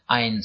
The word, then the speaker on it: Ayn: Ayn c'est le prénom, A-Y-N. – C'est une invention. Rand, R-A-N-D.